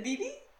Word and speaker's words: Bebe: Wait, did I say it wrong? – You said Bebe. – What is it, BB? – Yeah.